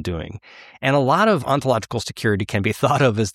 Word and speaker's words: ontological security: Ontological security. Did I say it right? – You did indeed.